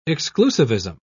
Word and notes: exclusivism: British English

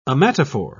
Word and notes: ommatophore: British English

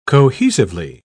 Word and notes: cohesively: British English

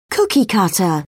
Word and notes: cookie cutter: British English

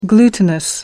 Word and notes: glutinous: American English

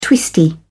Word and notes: twisty: British English

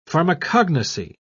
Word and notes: pharmacognosy: British English

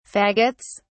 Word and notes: faggots: British English

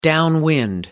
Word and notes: downwind: American English

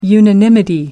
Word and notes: unanimity: American English

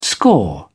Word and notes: score: British English

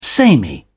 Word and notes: samey: British English

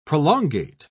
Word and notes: prolongate: British English

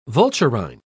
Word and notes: vulturine: British English

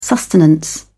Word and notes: sustenance: British English

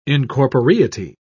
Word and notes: incorporeity: British English

